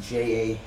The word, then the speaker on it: J.A.: We got the St. Louis Cardinals, J.A. Happ, visiting... – J. – Right, even though it's spelled J.A.